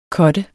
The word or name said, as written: cutter